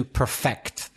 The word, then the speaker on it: perfect: He needed to perfection something, but you need to perfect. That's the verb. And remember, of course, the adjective is perfect where you stress the first syllable.